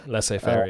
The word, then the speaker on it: laissez-faire: I mean, you can have a I don't know how to pronounce it, but it's spelled laissez-faire, but I think it's less fair. – Laissez-faire, yeah.